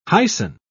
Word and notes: hyson: British English

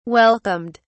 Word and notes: welcomed: British English

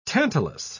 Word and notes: tantalus: British English

